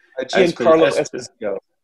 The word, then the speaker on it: Giancarlo Esposito: Giancarlo Esposito. – Giancarlo Esposito.